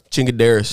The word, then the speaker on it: Chingaderas: This is Chingaderas, and how's it pronounced? – Chingaderas. – There we go.